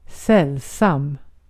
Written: sällsam

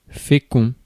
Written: fertile

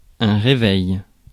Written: reveille

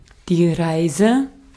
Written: journey

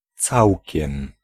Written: toute

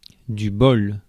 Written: luck

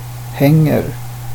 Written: hänger

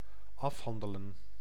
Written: settle

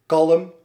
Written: kalm

